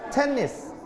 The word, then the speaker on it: tennis: ออกเสียงว่าเทนนิสเน้นที่พยางค์แรกนะครับเทนนิส